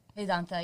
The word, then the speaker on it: Vedanta: Did I say that right? – Vedanta, yeah. – Vedanta.